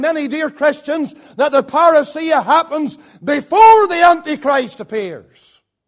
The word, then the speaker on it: parousia: It is the Greek word parousia.